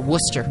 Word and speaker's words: Worcester: And then we're going to come back and we're going to Worcester.